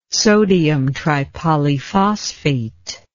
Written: sodiumtripolyphosphate

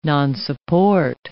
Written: nonsupport

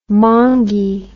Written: mongie

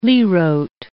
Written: lirot

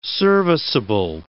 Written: serviceable

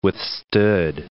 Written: withstood

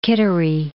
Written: Kittery Point

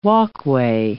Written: walkway

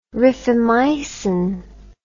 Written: rifamycin

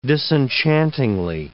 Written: disenchantingly

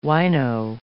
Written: wino

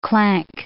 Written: clank

